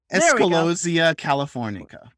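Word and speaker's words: Eschscholzia californica: Escolosia californica.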